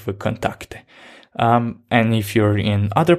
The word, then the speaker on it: VKontakte: It's actually pronounced VKontakte in Russia, and it's quite popular.